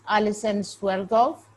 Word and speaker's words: Alison Swerdloff: Her name is Alison Swerdloff.